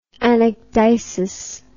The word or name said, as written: anecdysis